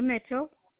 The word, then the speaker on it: Mitchell: Okay, my name's Linda Mitchell.